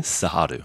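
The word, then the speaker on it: Cerrado: This area, how do you pronounce this? The Sahadu. – Sahadu.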